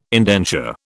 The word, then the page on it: indenture: British English